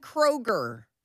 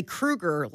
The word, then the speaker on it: Kroeger: Like, we say Kroeger like the grocery store. – Yes. And it's apparently Krueger, like Freddy Krueger from Nightmare on Elm Street.